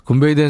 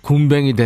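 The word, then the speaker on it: Goombay: 굼베이 댄스 밴드 이거 발음 잘해야 돼요. 굼뱅이 댄스 밴드 이렇게 하시는 분들 많아요.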